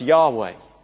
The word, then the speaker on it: Yahweh: In the Hebrew Bible, it's represented by the four letters, YHWH, that we pronounce Yahweh.